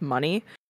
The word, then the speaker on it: Muni: It's spelled M-U-N-I, but I think it's supposed to be pronounced Money, by Money Long, featuring Jacob Lattimore.